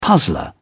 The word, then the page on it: puzzler: British English